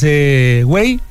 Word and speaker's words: güey: en vez de pronunciar pues güey, tal cual, lo empezaron a pronunciar como güey con W. – Oye, güey, ¿qué tranza?